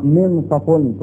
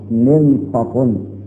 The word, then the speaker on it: منصف: منصف تنطق هكذا بكسر النون وتسكين النون وفتح الصاد منصف، عنده ايه منصف.